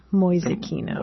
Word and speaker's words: Mäusekino: How do you spell that, Mäusekino? – M-A umlaut U-S-E-K-I-N-O, Mäusekino.